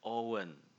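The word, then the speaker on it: Eoghan: spellingnya terus orangnya akhirnya bukan not Eogan Owen Eogan dibacanya Owen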